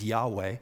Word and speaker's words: Yahweh: They would never say Jehovah, or the better pronunciation is Yahweh, but they would never say that, and they would never even write it.